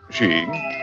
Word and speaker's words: skiing: Skiing, pronounced sheeing, is a sport that appeals especially to the rugged, wide-awake, out-of-doors individual.